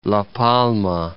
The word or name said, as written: La Palma